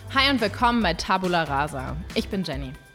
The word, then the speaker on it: Tabula rasa: Ähm, die äh, haben uns nämlich jetzt darauf hingewiesen, dass wir Tabula eigentlich völlig falsch aussprechen. Das heißt nämlich Tabula rasa und nicht, ich weiß nicht, wie wir es uns gesagt haben.